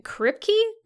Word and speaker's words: Kripke: Eric Kripke mm-hmm. is, I think, the correct pronunciation. It is.